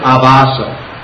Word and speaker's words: Abhasa: which is called Nam Abhasa. I don't have a thing to mark it up here, but Nama, and then A-B-H-A-S-A, with two long A's, Abhasa.